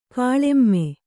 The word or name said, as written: ಕೆಳಮೈ